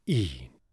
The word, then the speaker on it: e'en: And even here, as so often is pronounced with one syllable, e'en.